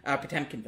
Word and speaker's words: Potemkin: Where it's like the training in the Potemkin Village sort of thing, except it's a British Potemkin Village. Potemkin Village, apologies. Pronunciation strikes again.